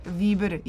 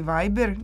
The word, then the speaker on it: Viber: Njima nije bitno da li izgovarate Viber ili Viber, njima je bitno da je koristite.